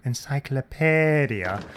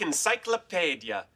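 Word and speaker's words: encyclopædia: Oh, you think it should be pronounced encyclopedia? It's a common mistake. But if you look up that squished together AE symbol in this here encyclopedia, you'll learn that it's a ligature derived from the Anglo-Saxon rune Ash.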